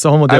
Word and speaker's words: Homo deus: Homo deus, is that right? – Yes, Homo deus.